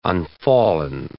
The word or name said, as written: unfallen